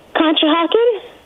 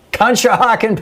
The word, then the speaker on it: Conshohocken: Conshohocken. – Conshohocken, Pennsylvania.